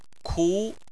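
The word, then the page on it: ku: tone 2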